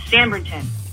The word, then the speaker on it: Sanbornton: Talking about pronunciation, Kelly always says Sanbrington wrong. He says Sanbornton. It's Sanbrington. Sanbrington, Sanbrington. Thank you. Have a great day. Love you guys. – When is the last time Sanbrington has been, what was it, craft fair I missed a couple weeks ago? – It's B-O-R.